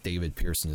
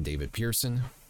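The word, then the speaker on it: David Pehrson: And for those of you that are listening in, it is David Pearson is spelled D-A-V-I-D, last name P-E-H as in Henry, R-S-O-N. So once again, David Pearson.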